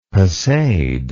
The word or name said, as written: passade